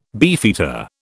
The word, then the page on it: beefeater: British English